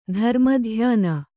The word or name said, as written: ಧರ್ಮಧ್ಯಾನ